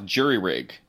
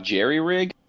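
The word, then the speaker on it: jury-rig: So, apparently, it's pronounced jury rig, not jerry rig.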